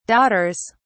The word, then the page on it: daughters: British English